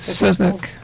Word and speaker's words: Suffolk: Suffolk.